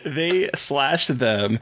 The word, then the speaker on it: They/Them: It's actually pronounced they/them. I got like 45 emails about it. It needs to be pronounced they/them for Junket interviews. Yeah, like that's how you have to pronounce it in Junket interviews.